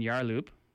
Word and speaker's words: Yarloop: But due to bushfire in Yarloop, which I don't know where that is.